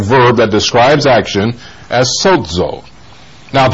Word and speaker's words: sozo: The Greek verb for save that would correspond with Yahshua is sozo. That's spelled S-O-Z-O, but the Z is pronounced like a D is in front of it, sozo.